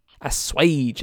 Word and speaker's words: assuage: Assuage, that's the word. That's how you say it, assuage.